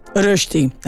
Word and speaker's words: Rösti: Io ho sempre Aromat, Rösti, ogni volta lo pronuncio malissimo. Rösti, ecco.